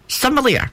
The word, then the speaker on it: sommelier: And the last one is a sommel. No, that's not how you say it. Sommelier. That's what it is.